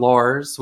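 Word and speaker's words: Lawers: It's a town called Lars, which is like spelled like lawyers without the Y.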